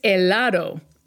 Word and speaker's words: helado: It's helado. Don't pronounce the H.